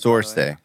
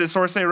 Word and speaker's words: Saoirse: Saoirse. – Is it Saoirse Ronan? – Yeah.